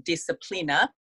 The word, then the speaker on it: disciplina: So the word discipline actually comes from an old Latin word, I believe it's pronounced disciplina, which means instruction and training.